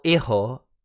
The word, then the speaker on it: hijo: Hijo, yeah, Hijo. Yeah, that is, the J Hijo. Is like, like Juan, Hijo.